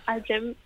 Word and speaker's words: iGEM: En jullie gaan ook nog naar de ik noem het maar IGMEM-wedstrijd in Parijs. Daar moet het tentoongesteld worden of bewezen worden dat het werkt. – Exact. Ja, ja, de IGEM, zo uitgesproken.